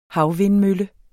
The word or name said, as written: havvindmølle